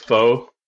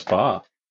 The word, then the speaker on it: pho: Ah, have you been calling it faux? Yeah. Mm, yeah, it's pho.